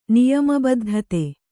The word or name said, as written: ನಿಯಮಬದ್ಧತೆ